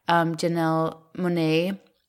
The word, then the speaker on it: Janelle Monáe: I also just love, I mean, Octavia Spencer, obviously, but I also just love Janelle Monáe. Is that how you say her name? – Like, it is.